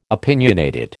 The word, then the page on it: opinionated: American English